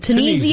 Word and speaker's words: Tunisia: Tunisia yeah.